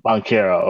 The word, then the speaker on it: Banchero: I'm sure if I'm pronouncing his name right. – Banchero. – Okay. – Yep.